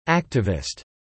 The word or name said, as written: activist